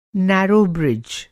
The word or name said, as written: नैरो ब्रिज